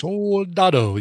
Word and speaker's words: Soldado: Soldado? – Soldado, yes.